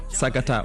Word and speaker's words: Sagata: Sagata, oui. Ouais. C'est Sagata, ça veut dire. – Okay. – Ça se prononce aussi Sagata.